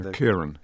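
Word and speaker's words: Ciarán: Charon Hines, I hope I'm pronouncing his name correctly. – Kieran.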